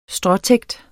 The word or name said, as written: benytter